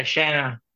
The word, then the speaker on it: Shanna: It's Shayna or Shana. – No. – S-H-A-N-N-A. Oh, okay. – Yeah, Shana. – Shana? Is that how you pronounce it? Yeah, A-E-W.